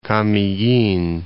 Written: Camiguin

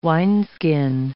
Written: wineskin

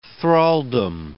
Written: thraldom

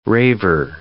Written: raver

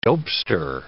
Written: dopester

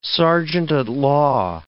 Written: serjeant-at-law

